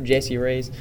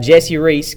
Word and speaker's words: Jesse Reece: Jesse Reece, kind of like the candy Reese's, yeah. But it's spelt with a C instead of a S.